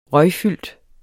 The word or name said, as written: røgfyldt